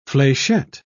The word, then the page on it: flechette: British English